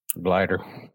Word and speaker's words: Gleyder: You know, you've got guys you've heard of like, and I'm going to probably butcher this, but is it Gleyder Figueroa? – Gleyder. – Gleyder Figueroa. Close enough.